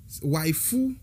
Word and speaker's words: waifu: dans la communauté nan communauté otaku ou bien communauté wibs lan waifu c'est wife en anglais wife qui di ma, femme et madame mariée ok wife et puis c'est prononciation wife en japonais